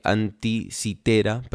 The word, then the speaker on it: Anticitera: Sí, incluso en la escena que, está, que está mm, eh, haciendo la subasta del, del fin de este, de la anteticera, eh, anticitera, perdón, lo, pronuncié mal,